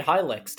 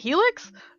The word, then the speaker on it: Hylics: You've played one of my favorite games ever made, Hylics. Tell me about it. – Hylics? You don't pronounce it Helix? – No. – What? Oh.